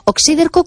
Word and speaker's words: oxíderco: Oxíderco, ¿cómo suena? O-X-I-D-E-R-C-O. – Exacto, y con acento en la I. – Oxíderco, sí, sí, es esdrújula.